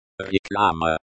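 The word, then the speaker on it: reklama (реклама): Remember to stress the first syllable in each word, especially reklama. – Reklama, so it's reklama, not reklama.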